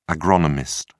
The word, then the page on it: agronomist: British English